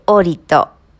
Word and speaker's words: ORID: ORID と書いてオーリット。